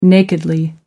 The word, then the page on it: nakedly: American English